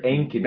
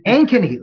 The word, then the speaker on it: Ankeny: One of the interesting things that I found out specifically looking at Ankeny, how did I do there? – Better, Ankeny. – better. – Ankeny, with the long A.